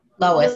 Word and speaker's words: Lois: And how do you say your name? Lois? Or Lois. Lois? Yeah, like Lois. Lois Lane.